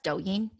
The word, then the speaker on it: Douyin: It's called, it's pronounced Douyin, yeah. Douyin. And it actually means vibrato, the word, but yeah, in Chinese.